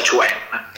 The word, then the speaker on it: Quan: Tai Chi è scritto, diciamo, Quan, che si pronuncia Chuan.